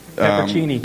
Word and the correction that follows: pepperoncini: potato chips. – Pepperoni-chini. – I know. That's why I didn't pronounce it. – Pepperoncini.